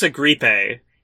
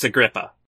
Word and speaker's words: Agrippa: He says Marcus Agrippe, which is not how you say Agrippa.